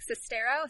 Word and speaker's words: Sestero: Okay, so it's from the co-author of the book, The Disaster Artist, which is a book by Greg Sestero.